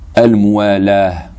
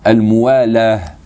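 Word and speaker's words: Al-mu'alah (الموالاة): Al-mu'alah, quand on s'arrête en arabe sur un ta qui n'est pas meftouh, on s'arrête sur un ha. Al-mu'alah, ça s'écrit al-mu'alatu, c'est-à-dire la consécutivité.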